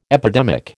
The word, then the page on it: epidemic: American English